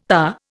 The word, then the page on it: TA: Indian